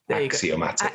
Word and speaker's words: axiomatic: is it automatic mind? – Axiomatic. – Axiomatic mind.